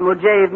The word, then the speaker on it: Mojave: Real Death Valley prospectors don't call it Mojave, Mr. Newton, but Mojave.